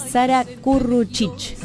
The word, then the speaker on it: Sara Curruchich: Voy a pronunciar despacito porque es Sara Curruchich.